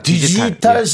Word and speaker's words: 디지털: 그 다음에 어, 이런 디지털 시계도 갖고 계실 겁니다. 디지털 시계도 네, 디지, 디지털 시계도 바, 저기 발음 좀 제대로 해주세요. 디지털이 디지, 아니라 디지털, 디지털 예.